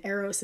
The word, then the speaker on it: Aros: Oh, and Aros is spelled A-R-O-S.